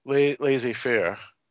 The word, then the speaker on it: laissez-faire: The walking dude, it's a strange guy because the walking dude lets his lieutenants run everything pretty much laissez-faire.